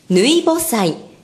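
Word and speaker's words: 抜穂祭: 日本の祭りは稲作のスケジュールに合わせて行われるものが多いのですが、今日はそのうちの一つ、ぬいぼ祭、ぬくほの祭りと書いてぬいぼ祭、こちらについて紐解いていきます。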